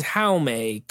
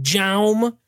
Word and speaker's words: Jaume: Now, directed by, his name is Jaume Colette Serra, but I always used to just call him Jaume because it's spelled with it. I like to say the hard J.